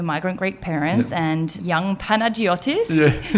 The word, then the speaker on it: Panagiotis: Is that how you say it? – Yeah, it's more with I spell it with a Y. Some people do spell it with a G, but... – Oh, Panagiotis. – Yeah.